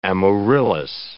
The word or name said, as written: amaryllis